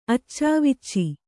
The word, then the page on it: ಅಚ್ಚಾವುಚ್ಚಿ: accāvucci